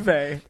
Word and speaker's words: Uwe: It's U-W-E. – So the W becomes a V. – In where? – That's how you pronounce it.